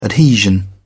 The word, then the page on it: adhesion: British English